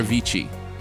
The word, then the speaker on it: Avicii: I think it's pronounced Avicii. It's either Avicii or Avicii. – Is it a VCR? – It's A-V-I-C-I-I. Avicii is what I'm going to say. Or Avicii. Either way. – A-V-I-C-I-I? – You got it.